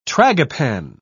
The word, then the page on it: tragopan: British English